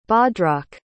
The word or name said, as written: Bhadrak